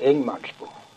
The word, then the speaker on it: Ingemansbo: Sen är det som heter Ingemansbo. – Jaha. Det uttalar vi för Ingemansbo.